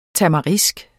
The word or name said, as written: Tamarix